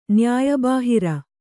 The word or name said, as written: ನ್ಯಾಯಬಾಹಿರ